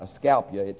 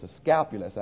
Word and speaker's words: Asclepius: The god, I think you pronounce this, Asclepius, isn't that, it's not Asclepius, it's Asclepius, I think.